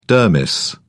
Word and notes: dermis: British English

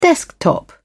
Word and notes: desktop: British English

